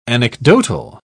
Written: anecdotal

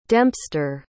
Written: dempster

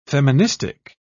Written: feministic

